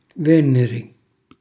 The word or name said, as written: venneri